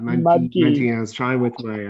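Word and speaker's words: Mudgee: Munchkey. – I love that. That is fantastic. I had never actually thought about it. Mudgy is how you say it.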